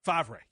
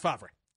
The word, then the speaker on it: Favre: Brett Favre? Is it? – Favre, yes. Pronounced Favre.